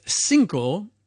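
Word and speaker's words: CNCO: And we start off with our first track of hot new tracks from a surprising number of places on the planet, with Cinco, pretty much all from the States. Today, they were four of them. Cinco is Spanish for five. They're spelt without the I, so it's C-N-C-O.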